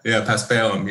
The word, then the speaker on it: paspalum: what we ended up with was seashore paspalum. Am I saying that correctly? – Yeah, paspalum. Yep.